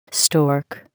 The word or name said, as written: stork